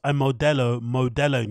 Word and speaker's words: Modelo: But listen to how Brendan pronounces Modella. Uh, um, Modelo.